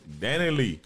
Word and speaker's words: Dani Leigh: Um, I cannot pronounce her name right. Is Dani Leigh? – That's it. – Dani Leigh.